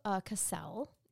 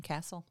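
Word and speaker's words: Kacel: I will say I really appreciated that Judge Matthew Cassell, in this case, did... I think he pronounces his name Castle.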